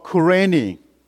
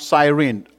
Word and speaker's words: Cyrene: In some of the languages, it's called Cyrene, and other people pronounce Cyrene.